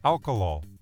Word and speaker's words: alkalol: Nope, I'm not mispronouncing that. It's alkalol, A-L-K-A-L-O-L.